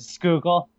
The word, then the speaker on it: Schuylkill: Nick Foles gets magical powers once he crosses like the Schuylkill River or whatever, however you pronounce it. – Schuylkill, yeah. Schuylkill. – And he's just like the magical trash-filled waters. – I tipped a canoe in the Schuylkill River.